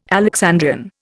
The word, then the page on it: Alexandrian: Indian